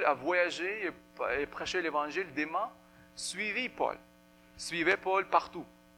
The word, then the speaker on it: Démas: Démas, ok, pas de S. On prononce pas le S. Démas.